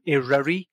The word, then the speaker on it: Eryri: So Snowdonia National Park, in terms of the word Snowdonia, we're now using Eruri, which is a very short little E-R-Y-R-I. So Eruri.